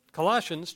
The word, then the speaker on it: Colossians: Colossians chapter 1.